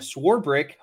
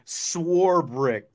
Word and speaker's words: Swarbrick: yes, Swarbrick. There's no there's no eight or there's no ch in Swarbrick.